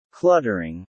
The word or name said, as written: cluttering